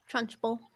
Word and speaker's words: Trunchbull: Did I say it right? – Trunchbull. – Trunchbull.